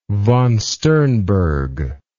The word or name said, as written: von Sternberg